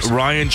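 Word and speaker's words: Rian: Ryan Johnson. It's R-I-A-N.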